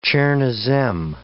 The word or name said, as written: chernozem